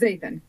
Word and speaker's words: Zaethan: Is it Zathian? – It's Zathian, yeah. – Zathian.